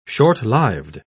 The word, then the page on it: shortlived: British English